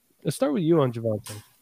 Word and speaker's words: Javonte: So let's talk about some Javonta. Is it Javonta or Javonte? I just mispronounced everybody's name now. – Go with Javonte.